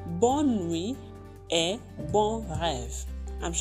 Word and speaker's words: bon rêve: How do you say Sweet Dreams in French. Bon rêve. Bon rêve. Bon rêve. Bon rêve. Rêve. Rêve. Bon rêve. Sweet Dreams.